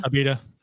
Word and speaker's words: Abita: Abita? – Abita, yeah.